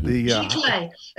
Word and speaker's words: Giclée: And you're getting into Giclée. Is that how you say it? With the Giclée. L-E-E.